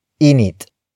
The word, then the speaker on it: init: In this context, nang serves as a linker between the adverb ubod to the root word it introduces, which is init. Init. Init. Init is spelled as I-N-I-T.